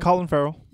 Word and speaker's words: Farrell: How do I say it? Farrell? – Colin Farrell. – Yeah. He like Will Farrell, but Colin instead.